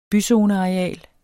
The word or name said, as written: byzoneareal